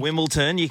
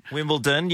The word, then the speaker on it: Wimbledon: Wimbledon. Wimbledon.